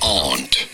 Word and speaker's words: aunt: How do you go about aunt. – Aunt. – It's how it's normally said. It may sound like you can simply ignore the U, but if you do...